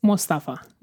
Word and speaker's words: Mustafa: Yes, Mustafa. – Mustafa. – Yes. – I was putting the accent on the wrong area.